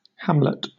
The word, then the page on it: hamlet: British English